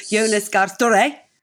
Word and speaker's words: Jonas Gahr Støre: And in terms of future jobs, we hear that the new Norwegian Prime Minister, Jonas Gahr Støre, is a Leeds fan.